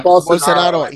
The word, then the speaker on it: Bolsonaro: Yeah, Bolsonaro.